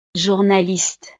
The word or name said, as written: journaliste